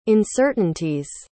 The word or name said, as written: uncertainties